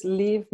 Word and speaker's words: Liv: So my artist name is Liv Møller, and it's spelled L-I-V, a Norwegian D-R.